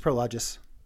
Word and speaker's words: Prologis: Prologic is hiring a cloud security engineer. – That's Prologic, not Prologis? – It's Prologis.